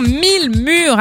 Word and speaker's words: Mil Murs: Et oui, et ça se passe avec l'association 1000 Murs. Alors 1000, hein, je le rappelle, l'orthographe M-I-L. M-U-R-S, parce que ça ne s'écrit pas comme ça se prononce.